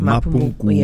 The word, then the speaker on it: Mapungubwe: is receiving the Order of Mapungubwe in... Am I saying it right? – Yes, the Order 100%. Of Mapungubwe.